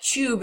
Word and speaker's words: Tube: It's very interesting, and it's also interesting to me that they don't they pronounce it the English way. Like, so it's purposefully that, on purpose. – Yeah, it's not Tube. No, it's T-U-B-E.